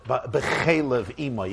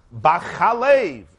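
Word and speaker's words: B'chalev (בחלב): Nonetheless, I don't mean to be the party pooper, but nonetheless, we don't pronounce it b'chelev ima, even though it's spelled in an identical fashion, beis, ches, lamed, beis. But it's not b'chelev, in the fat of its mother. It's in the milk of its mother. B'chalev, as we say, chalev or chalav.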